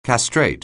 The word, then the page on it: castrate: anglais